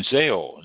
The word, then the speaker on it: Zhao: Zao, Z H A O, Zao. I believe it's pronounced.